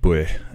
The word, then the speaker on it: buaidh: And for the Scottish Gaelic version, the word is spelled B-U-A-I-D-H, buih, and it means not just victory, but also impact or effect.